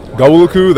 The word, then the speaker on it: Gawuluku: So it was like Gawuluku, that's how you pronounce it, Dying Gawuluku.